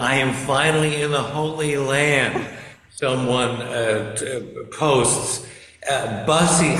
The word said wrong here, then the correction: Buc-ee's: how do you pronounce B-U-C? – Buc-ee's.